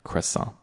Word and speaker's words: croissant: And then, yeah, so, but as I said, of course, speaking English, I would recommend croissant. But do not pronounce the T.